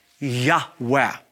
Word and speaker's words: Yahweh: As a result of that, the Gentile church began to refer to God as Jehovah rather than the original Yud-Heh-Vav-Heh, pronounced Abrethi, Yahweh. Now, let me say this as well. We are not totally certain, we're not 100% sure that God's sacred personal name is pronounced a breathy Yahweh because we don't have the accent markings and because for hundreds and hundreds of years, no one would say His name.